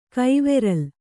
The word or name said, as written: ಕೈವೆರಲ್